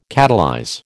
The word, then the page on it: catalyze: American English